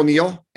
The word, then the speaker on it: Neal: So I'm the real Neil, N-E-A-L, and my last name is spelled S-C-H-A-F-F-E-R.